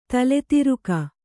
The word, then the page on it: ತಲೆತಿರುಕ: tale tiruka